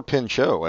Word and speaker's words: Pinchot: This had Bronson Pinchot and Bill Maher. – Or Pinchot, as he pronounces it, and everybody else.